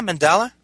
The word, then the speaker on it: mandala: I don't know much about, I mean, I know it's about mandala. Am I pronouncing that correctly? – Yeah, mandala.